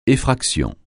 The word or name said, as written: effraction